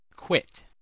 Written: quit

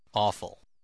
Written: awful

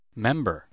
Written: member

